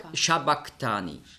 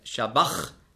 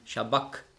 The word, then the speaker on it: Sabachthani: Im Griechischen gibt es keinen Sch-Laut. Es gibt nur das S. Und da muss man den Sch-Laut mit einem S schreiben. Und das CH im Griechischen, das ist ja ein Buchstabe, das Chi, das hat man ausgesprochen so wie ein C hinten im Gaumen. Also nicht Shabach, sondern Shabak.